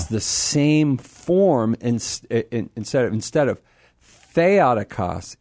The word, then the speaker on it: Theotokos: Oh, and that's why I go with Christotokos and then Theotokos is because I know where the accent there is. So anyway, just a little Greek trivia for those who might be interested. That's why I pronounce it Theotokos.